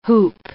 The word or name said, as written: hoop